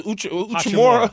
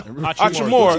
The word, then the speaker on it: Hachimura: Now look at how you say it, Uchimura. – Hachimura.